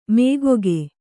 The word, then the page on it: ಮಗ್ಗೆ: magge